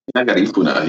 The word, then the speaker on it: Garifuna: Did I pronounce it properly? – Garifuna, yeah.